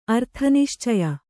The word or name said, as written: ಅರ್ಥನಿಶ್ಚಯ